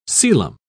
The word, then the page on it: coelom: British English